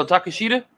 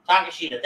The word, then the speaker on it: Takeshita: The Golden Elite versus Bullet Club Gold and how do you say is the other guy's name? – Who? – Shida. – Oh, I forget. – I always butcher this every time. – Oh, Takeshita? – Takeshita, thank you. There you go. Once I hear it, I'm good, but I can never say it.